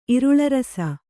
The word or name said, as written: ಇರುಳರಸ